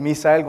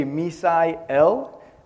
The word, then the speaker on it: Misael: And so I have an accent when I say my name. So when you hear me say Misael Gonzalez, I promise you I won't force you to say that accent. And so you can say Misael.